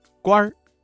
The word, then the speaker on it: 官儿: 这句朝廷上当官的认为谢安能够治国平天下，这句话当中啊，当官一词的官字加了一个卷舌音日，发生了语流音变，形成了儿化音，读作官儿。